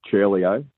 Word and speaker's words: Ciurleo: It's pronounced Ciurleo. C-I in Italian is pronounced C-H. All right, ciao.